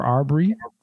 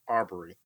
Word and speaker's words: Arbery: Ahmaud uh, Arbor or Arbery. Arbery. Arbery, uh, tw- what, uh, 25 years old.